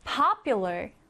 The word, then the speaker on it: popular: Popular. Popular. 인기 있는인데요. 자, 이 단어에서 강세부터 살펴보면 맨 앞에 이렇게 강세가 들어갑니다. 그래서 popular, popular 이라고 발음을 하고요.